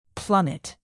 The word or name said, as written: plummet